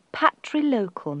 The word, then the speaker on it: patrilocal: The old term for the arrangement when a wife moves to her husband's family's household is a patrilocal marriage. Now that's spelt P-A-T-R-I-L-O-C-A-L. Patrilocal. A more modern term is virilocal. And we spell that V-I-R-I-L-O-C-A-L.